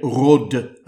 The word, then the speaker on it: Rhodes: The Tosca here is the soprano Jane Rhodes. Jane Rode, I suppose, is how it would be pronounced in French.